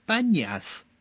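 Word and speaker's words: Banias: 但是呢，阿拉伯人对坡这个发音啊有些困难，所以呢，他们就把潘尼亚斯啊说成班尼亚斯。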